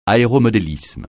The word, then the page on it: aéromodélisme: francais